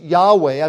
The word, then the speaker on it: Yahweh: I think that the best way we can describe it is basically Lord, capital L, capital O, capital R, capital D, is Yahweh. I've put it in your notes there, YHWH in the transliteration from the Hebrew, but it's pronounced Yahweh, that Yahweh basically means I am, the self-existent God, the God who always was and always will be.